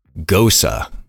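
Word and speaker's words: Gose: G-O-S-E. I had to look that up. I did not know. I would have never guessed gosa. – Are you sure that's how you pronounce it? – Gosa. Gosa. Like, it's like Rosa. Gosa.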